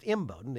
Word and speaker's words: Imboden: Well, you know, I tell people that Imboden, my hometown, is pronounced Imboden. The emphasis is on the I, Imboden. But sometimes when folks go away and go to college, it becomes Imboden.